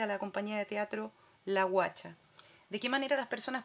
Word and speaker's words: Washa: La Guacha se escribe W-A-S-H-A.